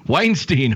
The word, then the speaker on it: Weinstein: Boy, what starts with a W and rhymes with Einstein? Weinstein, Harvey.